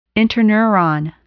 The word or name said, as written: interneuron